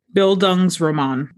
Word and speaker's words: Bildungsroman: Bildungsroman.